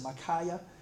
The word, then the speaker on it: Micaiah: Uh, just in my scripture reading this morning, I read about, I think it's his, you pronounce it Micaiah.